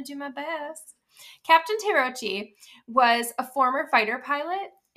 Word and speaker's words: Terauchi: And I asked him how to pronounce this, and it's, I'm going to say it wrong, even with his instructions, but it's Terauchi. – Terauchi.